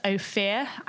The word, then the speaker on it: Ophir: But one of our favourite is Ophir. I think that's how you pronounce it. It's O-P-H-I-R. – That's how I pronounce it. – We'll say that's right for now.